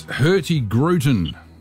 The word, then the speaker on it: Hurtigruten: What in the world, sir, is hurtigruten? – Well, you've started off well because you pronounced it spot on.